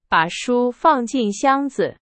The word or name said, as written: 箱子